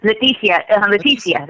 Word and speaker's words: Leticia: Leticia had, is it Leticia or Leticia? Leticia. – Leticia. That's fine. – Leticia. – The T is silent.